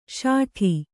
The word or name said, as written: ಶಾಟಿ